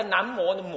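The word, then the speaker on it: 南無: 就好像那个南摩的“ 摩” 字啊。南摩的摩字可能是古音，这个我对还没查。那个摩字呢，无念成摩，南无那北方才有啊，南边无，是不是啊？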